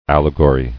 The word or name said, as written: Allegorist